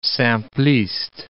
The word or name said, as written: simpliste